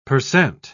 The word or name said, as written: percent